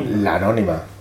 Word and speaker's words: Lanónima: Gracias a Luis por el esfuerzo y a la nómina. Lo he dicho bien, ¿no? – Anónima. – La anónima. Que es la anónima.